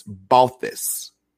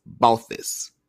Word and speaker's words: Balthvs: It's a little difficult, but this is how you pronounce it. – Right. – And it's Balthus. – Okay. Let me. – That's spelled B-A-L-T-H-V-S. Balthus.